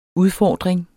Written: udfordring

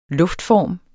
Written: luftform

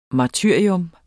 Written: martyrium

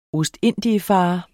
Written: ostindiefarer

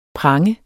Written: pranger

